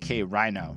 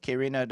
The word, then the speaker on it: K. Reno: I think it was one of those album reviews where I gave over a 4.5, and the entire time we said K. Rhino. So when you go watch that review, if you do care to, keep in mind we have learned how to say the man's name properly.